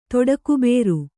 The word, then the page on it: ತೊಡಕುಬೇರು: kannada